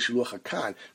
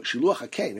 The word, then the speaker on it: shiluach hakein: If you're saying kan tzipor, then it's pronounced kan. So shiluach hakein. Everybody says shiluach hakan. It really is shiluach hakein.